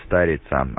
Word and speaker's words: Старица: В QTH правильно будет ударение на первую букву А, Старица, от слова «старая».